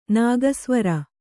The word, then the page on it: ನಾಗಸ್ವರ: nāga svara